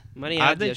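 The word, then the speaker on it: Maren Ade: Como é que fala o nome da Marinha Mare D? AD. Marinha AD? Marinha AD, acho que chama.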